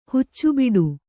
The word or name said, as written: ಹುಚ್ಚುಬಿಡು